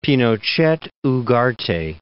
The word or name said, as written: Pinochet (Ugarte)